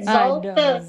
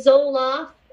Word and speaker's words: Zolfo: You did, Zolfo. Some people want to say Zoloft. I'm not the antidepressant.